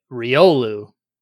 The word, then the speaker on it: Riolu: My first pick for Gen 4 is going to be Riolu. – Oh, okay, okay. Spelled exactly as it's pronounced, R-I-O-L-U.